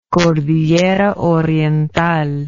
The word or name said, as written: cordillera oriental